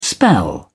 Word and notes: spell: British English

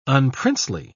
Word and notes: unprincely: British English

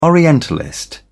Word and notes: orientalist: British English

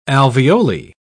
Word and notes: alveoli: British English

